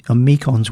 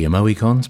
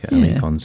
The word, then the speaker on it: Amoekons: Yeah, A-M-O-E-K-O-N-S. Amikons, we're calling, because as in amoeba. – Yeah. – We think it might be sort of related there a little. – It could be Amoicons, but no, I think Amikons sounds better. – And the track was The Oldie, as in T-H-E-E, Oldie, Trip to Jerusalem.